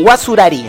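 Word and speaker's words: Guacurarí: Guasurari, en realidad es. Guasurari. Se pronuncia así porque era un apellido portugués, de hecho. Claro. Este, va, era una mezcla de guaraní, pero con la pronunciación y con la mezcla que generó el portugués con el guaraní, básicamente, este, y que marca en todo caso esta conmemoración tan propia.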